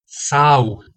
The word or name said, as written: sau